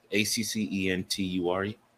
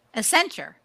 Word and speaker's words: Accenture: Uh, Accuture Accenture, A C C E N T U R E. – Accenture. – Accenture. There we go. Don Queen, Don King. – You know, Accenture.